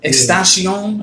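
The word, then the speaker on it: XXXTentacion: Extension or some shit like that? – Extension.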